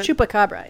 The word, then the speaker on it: chupacabra: Chupacabra, is that it? – Chupacabra, yeah.